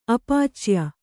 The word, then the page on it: ಅಪಚಯ: apacaya